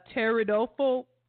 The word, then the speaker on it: Pteridophobia: And this is the P is silent. Pteridophobia. – What is that a fear of? – Fear of ferns. F-E-R-N-S.